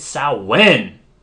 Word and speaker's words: Samhain: Although, I couldn't help but scream at the TV when Mulder pronounced it Samhain. – Yeah, he did say that. – I was like, motherfucker, it's Samhain.